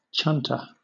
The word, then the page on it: chunter: British English